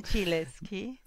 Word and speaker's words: Gentileschi: Gentileschi.